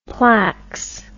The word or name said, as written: plax